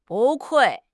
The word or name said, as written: 不愧